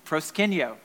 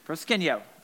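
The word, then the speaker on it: Proskenio (προσκυνέω): The Greek word is unpronounceable, but it is, is pronounceable. Proskenio. Proskenio.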